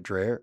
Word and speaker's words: Drehle: D-R-E-H-L-E. How would you say that? – Dave Dreher.